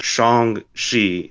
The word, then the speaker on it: Shang-Chi: Yeah, Shang-Chi is the pronunciation, not Shang-Chi, turns out.